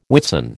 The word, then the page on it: Whitsun: American English